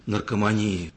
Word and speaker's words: наркомании: Может показаться, наверное, странным, что в стране, в которой такое огромное количество проблем, нищих людей, болезней, наркомании, так говорят психиатры, они ставят ударение именно, как я поставил, вдруг люди борются за то, чтобы люди отказались от черной икры.